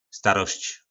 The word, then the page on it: starosc: po nasimu